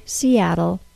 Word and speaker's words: Seattle: The final sound of the word Seattle is a syllabic L. A syllabic L is an L sound that has no vowel sound in the syllable with it.